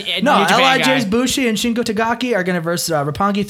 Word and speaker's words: Takagi: L.I.J., which is Bushi and Shingo Takagi, or is that how you pronounce his last name? Shingo Takagi? – Yeah.